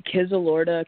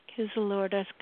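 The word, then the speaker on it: Kyzylorda: Born June 5th, 2001, in Kizilorda, Kazakhstan.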